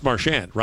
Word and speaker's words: Marchand: No, actually, the Bruins put out a thing and said, it's Marchand, rhymes with sand.